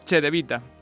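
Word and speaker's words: Cedevita: El Real Madrid sufre para ganar en Kaunas, mientras que el Unicaja hace lo propio ante el Che de Vita, que como estamos tan quisquillosos con la pronunciación, Che de Vita.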